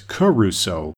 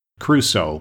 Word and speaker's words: Crusoe: I grew up with the habit of pronouncing it as Caruso instead of Crusoe, but the C and the R are side by side.